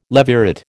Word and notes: levirate: American English

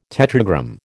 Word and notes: tetragram: American English

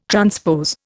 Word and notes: transpose: Indian

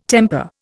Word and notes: temper: Indian

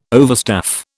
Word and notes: overstaff: British English

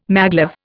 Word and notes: maglev: Indian

